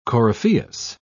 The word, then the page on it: coryphaeus: British English